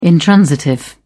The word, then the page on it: intransitive: British English